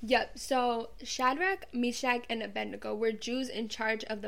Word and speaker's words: Abednego: How do you pronounce it? – Abednego.